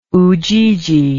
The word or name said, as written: ujiji